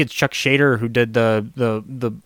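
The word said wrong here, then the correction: Schaden: Um, Chuck Shaden,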